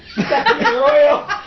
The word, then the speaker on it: Arroy: The forest Arroy. – But how do you spell that? – A-R-R-O-Y. – Arroy. – Arroy. – Yo. – Yes, like Arroy. We're going to find him in the Arroyo.